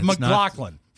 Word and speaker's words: McLaughlin: It's McLaughlin. Forgive me. – You don't pronounce the G-H.